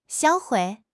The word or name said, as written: 销毁